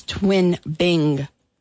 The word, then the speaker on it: Twin Bing: You and I have both said this incorrectly. It's twin bing. – Twin bing? – I said bling. – Oh. – And you said bang. It's twin bing. – Twin bing with an I. – Yeah, B-I-N-G.